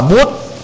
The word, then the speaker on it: Buddha: anh hóa là buddha đó thì người ấn độ đọc là bút